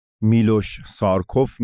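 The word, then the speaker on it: Miloš Šarčev: که حالا تلفظهای دیگه ای هم براش به کار میبرن میلوش سارکوف میگن بعضیا بهش و چیزهای دیگه میگن ولی ما خب مصطلح هست میلوس سارسوف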